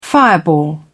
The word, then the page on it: fireball: British English